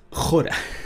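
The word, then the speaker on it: Jora (ὥρα): Entonces, aquí la Reina Valera no nos ayuda mucho, porque en realidad la palabra hora del versículo 42, ¿tú sabes cómo se dice en griego? – Jora. – Hora. Se dice igual.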